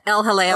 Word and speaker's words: El Jaleo: El Yahail. Help me. My pronunciation's bad. – El Jaleo. – El Jaleo.